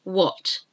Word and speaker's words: what: So the first one with the silent letter H is, you probably know some of these, and probably all of them, let's hope. Anyhow, let's go through them together. What? W-H-A-T.